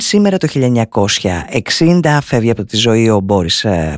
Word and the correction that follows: Μπορίς: Πάστερνακ, Μπορίς, για την ακρίβεια Μπορίς είναι το όνομα, εκεί τονίζεται στα ρωσικά, Ρώσος συγγραφέας, ο οποίος τιμήθηκε με το βραβείο Νόμπελ Λογοτεχνίας το 1958 για το έργο του Dr. Zhivago.